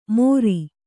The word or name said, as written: ಮೋರಿ